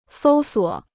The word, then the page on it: 搜索: sōu suǒ